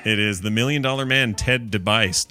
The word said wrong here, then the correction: DiBiase: DiBiase. – There you go.